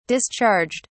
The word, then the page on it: discharged: British English